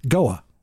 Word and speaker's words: Goa: Goa.